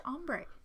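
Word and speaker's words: ombré: H-O-M-B-R-E. – Well, this is spelled S-O-M-B-R with the dash above it, E. – Sombre? – No, it's pronounced ombre.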